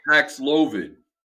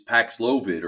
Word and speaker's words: Paxlovid: But I think it's Pavlokic? – Paxlovid. So they're trying to make it rhyme with COVID in this in the new commercial. So they said, when you have COVID, it's Paxlovid or something creepy and seemingly,